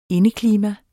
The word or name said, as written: indeklima